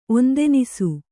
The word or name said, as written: ಒಂದೆನಿಸು